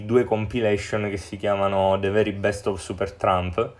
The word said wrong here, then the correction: Supertramp: eh, Super Tramp più che altro si pronuncia,